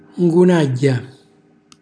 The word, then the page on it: ngunagghja: calabrese